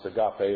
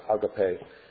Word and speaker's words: agape: And the original Greek word, sometimes pronounced agape or agape, which is here translated charity, might better have been rendered love.